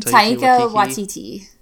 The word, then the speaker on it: Waititi: Do you know how to pronounce his name? – Taika Waititi.